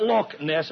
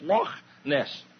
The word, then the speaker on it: Loch Ness: It's not Loch Ness, it's Loch Ness. Say Loch Ness.